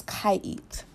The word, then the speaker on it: Kaiit: Her name is Kaiit. That's spelled K-A-I-I-T. Again, that's K-A-I-I-T.